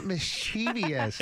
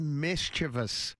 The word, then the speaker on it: mischievous: It's not mischievous. – It sounds right. – It does sound right, but it's not. Right. It's not. It's mischievous. Mischievous. Because if you look at the way it is spelled, all right, M-I-S, mischievous, right? So it's C-H-I-E-V-O-U-S. There's no I there. If it was mischievous, there would be an I.